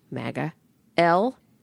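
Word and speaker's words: Magalhães: And his last name is spelled M-A-G-A, MAGA, L-H-A-E-S.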